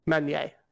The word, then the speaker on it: Meunier: I'll throw in briefly here, talking about the etymology and the word origins, the fact that Meunier, am I saying it right? – Meunier. Yes.